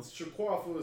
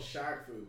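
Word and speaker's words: Shaq Fu: Shaquafu is how we pronounce it when we were kids. It's actually called Shaqfu. We didn't know that. We assumed it was fancy, so we all called it Shaquafu.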